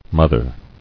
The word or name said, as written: Mothered